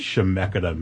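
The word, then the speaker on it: Chemeketa: Before we continue, what does Shemeketa mean? – Shemeketa, hard C-H there.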